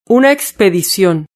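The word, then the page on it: una expedicion: Spanish (Spain)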